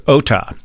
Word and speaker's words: Ohta: District Court Judge Jinsook Ota, I believe is her, how you pronounce her last name, O-H-T-A.